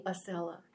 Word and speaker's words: Accela: Oh, and it's, am I pronouncing it right? Acela? A-C-C-E-L-A? – Acela, yeah.